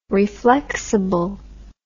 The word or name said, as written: reflexible